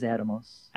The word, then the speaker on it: Atomos: If I may, I don't like to correct you because you often know more than I do, but it is Atomus.